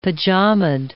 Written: pajamaed

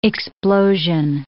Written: explosion

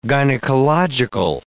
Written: gynecological

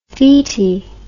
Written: feti

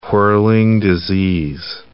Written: whirling disease

